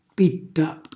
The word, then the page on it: pitta: calabrese